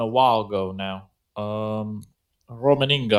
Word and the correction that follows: Rummenigge: I can't pronounce his name. He's one of the directors from the Bayern Munich. Rummenigge.